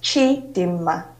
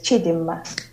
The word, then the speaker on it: Chidimma: Well, you can pronounce it as Chidima, like God is good. So it's Chidima.